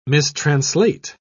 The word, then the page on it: mistranslate: British English